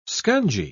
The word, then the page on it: scungy: British English